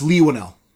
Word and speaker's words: Leigh Whannell: Am I pronouncing that right, or is it Lee? – It's Leigh Whannell.